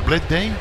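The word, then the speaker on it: Bleday: Black Day. ¿Cómo se pronuncia? ¿Black Day? – Black Day. – Ok, Black Day.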